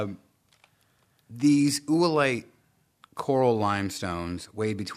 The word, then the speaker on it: oolite: But it's it's spelled O O L I T E. Mm-hmm. So oolite. Oolite. – Ooh, it's light. It's, it's not Ooh, light.